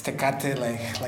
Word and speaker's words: Tecate: Tecate, if I'm saying that right. – Yes, Tecate, like the beer.